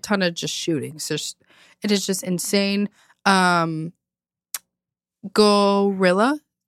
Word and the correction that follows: GloRilla: That's how you say her name, right? – Glorilla.